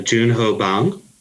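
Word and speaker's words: Bong Joon-ho: It's uh, Junho Bang. – Is that how you say it? – Well, yeah. So uh, typically, the the Korean format is last name first.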